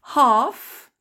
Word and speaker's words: half: Past, passé de, to, quarter, ça vient du français, quartier, half, attention, le L ne se prononce pas, midday, it's noon, midnight, in the morning, in the afternoon, in the evening.